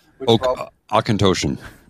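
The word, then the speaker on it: Auchentoshan: How did you pronounce? I said Oshin-Toshin. – Oshin-Toshin.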